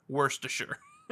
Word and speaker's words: Worcestershire: I think when you put when you put a little bit of Latin accent when pronouncing Spanish words and say Shire when it should be sure, Worcestershire.